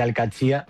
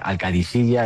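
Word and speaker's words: Al-Qadsiah: Bueno, se suele decir Al-Qadsiya, pero también hay gente que lo dice Al-Qadisiya. Es que es difícil, ¿eh? Ah. No es fácil, pero Al-Qadsiya es lo más fácil.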